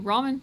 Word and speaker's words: Raman: Raman, like the noodles, but it's spelled differently.